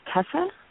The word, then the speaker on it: Tessa: My name is Tessa. – How do you spell that, please? – It's T-E-S-S-A.